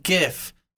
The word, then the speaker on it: GIF: Number one, it is pronounced GIF. I don't care what anybody else says. It stands for graphics, interface, format. It's GIF. They kept saying GIF in the episode and it bothered me. It's GIF.